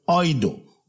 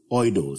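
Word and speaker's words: Oido (εἴδω): Now the Greek word used here that we might know here is the Greek word oido. E-I-D-O. Oido. Some spell it A-I-D-O. Oido. Or oido. Either of them is the same Greek word, which implies to recollect.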